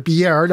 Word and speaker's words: Biard: Nexstar Chief Executive Michael Biard, I think that's how you pronounce it. It's spelled B-I-A-R-D. I think Biard is the only way to pronounce that.